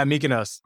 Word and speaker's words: Mykonos: Is it Mykonos, right? Is that how you pronounce it? – Yeah, Mykonos.